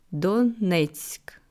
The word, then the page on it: Donezk: slow